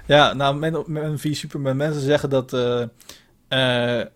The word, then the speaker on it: v Superman: En toen kwam in 2016 Batman v Superman. Dus je moet het niet uitspreken als versus, maar V Superman. Er staat geen S.